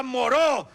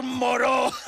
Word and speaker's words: Moreau: ¡Doctor Murró! ¡Doctor Murró, Carmen! – ¡Se pronuncia Moró! doctor Moreau.